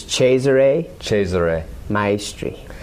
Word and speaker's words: Cesare Maestri: Well, the correct pronunciation, as near as I can do, because I don't speak Italian, is Cesare Maestri.